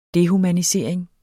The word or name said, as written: dehumanisering